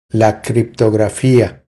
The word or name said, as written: La criptografia